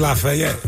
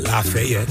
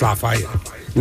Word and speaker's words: Lafayette: Just like it's Lafayette. It's not Lafayette. It's Lafayette.